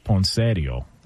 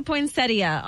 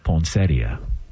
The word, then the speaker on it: poinsettia: Well, the in-laws would call it poinsettio. – Oh, gosh. – Okay. What up, Ray? You know what I'm saying? – Oh, God. – Can we get the proper pronunciation on this? – It does say poinsettia online.